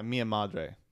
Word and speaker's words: Mia Madre: New on DVD this week: Army of One, Mia Madre. Am I saying that right? – Mia Madre.